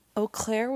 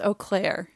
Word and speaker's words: Eau Claire: And well, to be clear, it's pronounced, well, to be clear, hold on, now I'm very confused. It's pronounced Eau Claire. – If you're a French snob. – No, really? Shut up. – The actual French pronunciation, and which I'm sure many people also say, is Eau Claire, but many local residents.